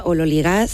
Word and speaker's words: ololigas: la parte de los ololigas, que ni siquiera sé si he pronunciado bien. Cuéntales a los oyentes qué es eso, Elena. – Teóricamente se pronuncia ololigas según la transcripción fonética del griego al español,